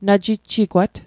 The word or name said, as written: najijjigwat